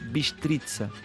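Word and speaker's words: Bistrița: Pronuncia-se Bistritza, porque debaixo do segundo T está uma cedilha que faz o T passar a T.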